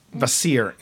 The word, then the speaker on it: Kvasir: Dogfish makes a lambic, or a lambic-esque, a Scandinavian-style beer called Vasir, and it's spelled K-V-A-S-I-R, Vasir.